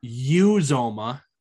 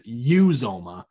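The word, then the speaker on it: Uzomah: CJ Uzoma, I've been saying his name wrong forever. It's Uzoma.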